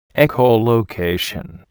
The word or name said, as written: echolocation